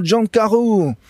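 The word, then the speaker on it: John Carew: John Carreau, som man uttaler Jon Carew på fransk.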